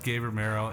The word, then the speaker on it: Gabe Romero: Well, okay, so my guests today are Gabe Romero and Dan Glynn.